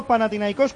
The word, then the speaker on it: Panathinaikos: Vamos, oye, y el Panathinaikos, ese que dices con acento en la O, cuidado también, ¿eh? – Oye, cuidadito.